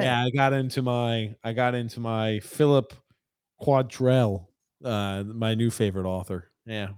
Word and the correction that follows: Quantrell: How would you say that? That's Philip Quantrell, isn't it? I know it's backwards Quantrell. – For you. – Yeah. Yeah. Quantrell. Philip C. Quantrell.